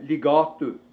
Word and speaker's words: Ligatu: Sista byn före Näsåker hette Ligatu med betoning på mellanstavelsen.